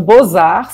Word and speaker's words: Beaux-Arts: Então, Bozar se escreve B-E-A-U-X, Art, A-R-T-S. Aí eles, eles mudaram o nome do museu para Bozar, assim, B-O-Z-A-R, que é como se pronuncia.